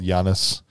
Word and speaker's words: Janus: There's something about Janus and this clone. – It's clearly Janus. – Janus, whatever. It's spelled Janus. And we say Janus, it's like anus with a J on the front of it.